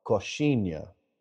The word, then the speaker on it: Coxinha: Coxinha, spelled Coxinha.